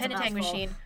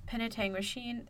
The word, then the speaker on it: Penetanguishene: Penetanguishene Mental Health Center. – That's Penetanguishene, Ontario. – Well, at least you can pronounce it. I can't. Don't make me try. – Yeah. Penetanguishene.